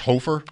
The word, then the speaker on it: Hofer: This guy's name is pronounced Hofer. – I think that's right. – It should be. – I think so. – H O F E R. It should be H O F E R. Hall of Fame.